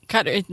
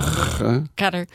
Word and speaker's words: Qatar: Well, it's Qatar. There's a little glottal, you know, Qatar. I can't say it exactly right.